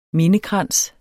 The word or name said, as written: mindekrans